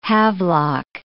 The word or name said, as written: havelock